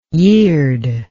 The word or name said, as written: yird